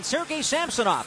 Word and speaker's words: Samsonov: And Sergei Samsonov, that's how we've decided to say his name for this playoff series, has always faced the Sharks and played against them very well.